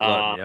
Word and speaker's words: Luton: So my first quarterback is Jake Luton. Is that how you pronounce his last name? – Jake Luton, yep.